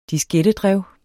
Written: diskettedrev

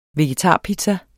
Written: vegetarpizza

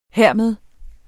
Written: hermed

